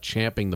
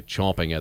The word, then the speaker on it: champing: They call it champing the bit. And you can understand over the years people have heard that and said it wrong. And it's obviously acceptable now to say chomping at the bit.